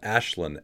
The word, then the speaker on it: Aislinn: Yoga with Ashlyn, A-I-S-L-I-N-N, that's how you spell it.